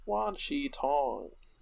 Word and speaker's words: Wan Shi Tong: Did I say it right or did I not? – Wan Shi Tong. – Yes. – Yeah, you said it right.